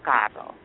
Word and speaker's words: caro: Say it right? – Caro. – Like you got it. Yeah, caro.